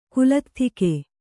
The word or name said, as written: ಕುಲತ್ಥಿಕೆ